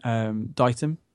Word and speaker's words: Ditum: It's just my name, Nathan Ditum. – Oh my god, I've been pronouncing your name wrong.